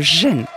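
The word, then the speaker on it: gen (仁): Alors en Chine, c'est le ren. Alors je vais essayer de le prononcer euh, en chinois. C'est, on dit le gen.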